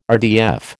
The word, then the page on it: RDF: American English